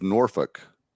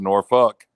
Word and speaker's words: Norfolk: But everybody pronounces it. They don't live there. Norfolk, Virginia. And I was like, that's not the way you pronounce it. – That's Norfolk.